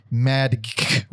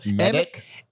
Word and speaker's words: Maedke: Christopher Madick Russell. – Madick? – Madick? M-A-E-D-K-E. It's easier to spell it than it is to fucking say it.